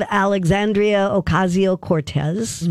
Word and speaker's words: Alexandria Ocasio Cortez: Uh, Representative Alexandria Ocasio Cortez.